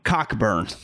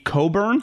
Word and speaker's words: Cockburn: That is not how you say his name. It's Kofi Coburn. – Coburn. I've been saying it wrong for 2 weeks. I learned that this week that it's Coburn.